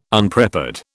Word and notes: Unprepared: British English

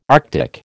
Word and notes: Arctic: American English

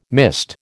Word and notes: mist: American English

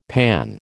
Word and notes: panne: American English

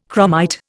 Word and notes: chromite: Indian

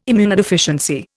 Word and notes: immunodeficiency: Indian

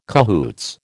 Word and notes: cahoots: American English

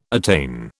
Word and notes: attain: British English

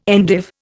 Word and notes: endive: Indian

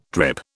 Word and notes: drip: British English

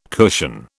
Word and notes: cushion: British English

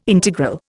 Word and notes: integral: Indian

